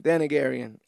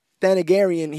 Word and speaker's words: Thanagarian: And, he doesn't know about the Targaryen? Is that how I'm saying that right? – Thanagarian. – Ah, I got to be a better diplomat. Thanagarian.